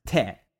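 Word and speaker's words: te (茶): And in the Minna variety of Chinese, the character for cha is pronounced te.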